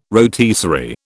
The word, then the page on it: rotisserie: British English